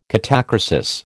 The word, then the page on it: catachresis: American English